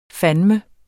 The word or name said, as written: fandeme